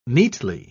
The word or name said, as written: neatly